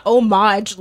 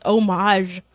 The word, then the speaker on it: homage: It's because it's not homage like Omarion. It's homage like Zhuzh.